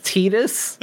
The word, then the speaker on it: Tidus: I believe it's pronounced Tidus, but I would rather say Titus if you're going to ask me.